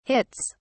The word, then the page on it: hits: British English